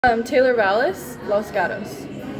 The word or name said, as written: Taylor Vales